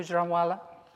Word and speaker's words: Gujranwala: It was written in December 2002, and it's from Gujranwala. Is that how you pronounce it? Yeah. Gujranwala.